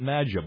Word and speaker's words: matcha: I pronounce it maja, but we're you can call it anything a little heart desires. – M-A-T-C-H-A.